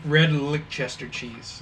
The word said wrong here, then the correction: Leicester: Actually, my mom said that we said it wrong every single time. It's actually red. I think she said red Leicester. – Leicester. – Red Leicester cheese.